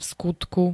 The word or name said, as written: vskutku